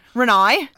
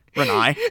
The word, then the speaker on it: Renai: We cut to the present, and this is where we see Renee Lambert, Josh's wife. – Renai. I'm sorry, guys. When we watched this film with the subtitles on, comprehending that that's how it's spelled, R-E-N-A-I, Renai.